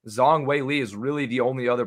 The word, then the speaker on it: Zhang Weili: I've been pronouncing it Zhang. But apparently it's Zhang Weili.